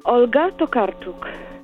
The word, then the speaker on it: Olga Tokarczuk: So when I say I spoke with Olga Tokarczuk, pretend I pronounced it correctly, like this. – Olga Tokarczuk.